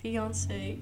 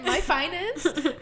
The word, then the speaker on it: fiancé: Maar ik weet niet hoe je dat moet uitspreken. Fiance? fiancé. Fiancé. Ja. Finance. – Finance. My finance.